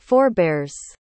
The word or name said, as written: forebears